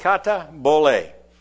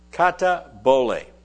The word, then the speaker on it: katabole: The word foundation is the Greek word kata bole. Kata bole. It is spelled, transliterated into English letters, K-A-T-A-B-O-L-E. It's a compound word.